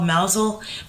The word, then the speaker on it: Mousehole: It's called Mousel, but it's spelled Mousehole.